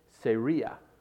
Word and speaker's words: Zeruiah: It says, now these three sons of, you want to pronounce it Zeruiah, but it's actually pronounced in the Hebrew Seria.